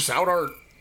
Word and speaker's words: South Heart: Yeah, the step grandpa Louie was down there in South Art. That's how you say it. It's not South Art.